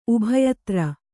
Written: ಉಭಯತರು

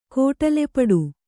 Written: ಕೋಟಲೆಪಡು

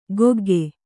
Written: ಕೊಗ್ಗೆ

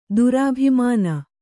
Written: ದುರಭಿಮಾನ

